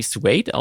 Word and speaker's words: Suede: spricht man die Suede aus, ja? – Richtig, ja.